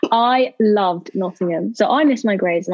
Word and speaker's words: Nottingham: Nottingham? Nottingham.